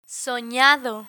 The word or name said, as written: sonado